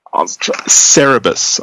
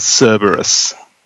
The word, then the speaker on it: Cerebus: Yeah, no, I shouldn't be so complex, but I always get stuck on Cerebus. I often say it as Cerberus.